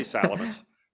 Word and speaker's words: Salamis: I want you to say you are free to pronounce almost any Greek word any way you want, because the way the ancient Greeks pronounce them is in many cases not the way a modern Greek would pronounce it. So feel free. I say Salamis. – But, Salamis, okay. – And that's been kind of traditional in English for a long time.